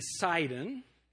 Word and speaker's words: Sidon: Tyre and Sidon, S-I-D-O-N, that's the way that we would pronounce it now. T-Y-R-E, Tyre and Sidon, those are the two cities that are being spoken of here.